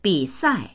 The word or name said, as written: bi3sai4